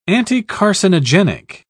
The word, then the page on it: anticarcinogenic: British English